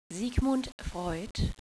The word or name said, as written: sigfreud